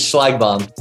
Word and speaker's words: Schlagbaum: It's Schlagbaum. – Schlagbaum.